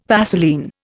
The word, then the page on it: Vaseline: Indian